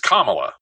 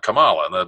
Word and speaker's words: Kamala: Cause it's Kamala, not Kamala.